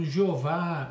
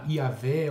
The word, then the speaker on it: Javé: É, e a pronúncia é, mais aceita seria, no caso, Jeová, Iavé ou até Javé.